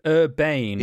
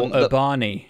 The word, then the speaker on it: Urbane: Or Urbani, I've heard it pronounced once. – Oh, really? I'd love to know by whom. But I think it was ironically. Yeah. I hope it was ironically on a podcast last week.